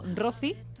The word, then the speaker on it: Rocci: Bueno, se escribe Rochi, es R-O-C-C-I, como Rozi, como si fuera Rocío. Pues le quitamos la O, le ponemos otra C, Rozi.